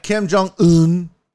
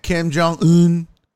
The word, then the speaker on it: Kim Jong-un: I said it right. Kim Jong-un. And if you don't know that that's how you say it, well, then you don't know. It's 1,000% Kim Jong-un. If you say it any other way, you're wrong.